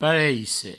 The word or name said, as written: pareisse